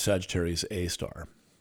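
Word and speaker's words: Sagittarius A*: It has a name, Sagittarius A asterisk, pronounced Sagittarius A star.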